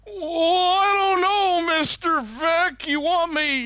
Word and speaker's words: Veeck: No. Not Mike Vic, but Mike Vec. – Yeah. – Like V-E-E-C-K. – Mike Veek! – Yeah, I think it's pronounced Vec. All the news videos that I saw, they actually pronounce his name Vec, as far as I'm...